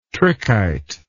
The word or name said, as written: trichite